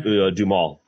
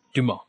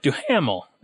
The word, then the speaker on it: Duhamel: Do you know how to pronounce Josh's last name? – Dumal. – Dumal. I was going to be like, Duhamel. That's not right.